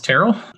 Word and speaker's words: Terrell: so my name is terrell brown um, it's spelled like terrell but pronounced terrell i've uh,